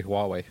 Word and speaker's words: Huawei: Haihui. – Huawei. – I say Huawei.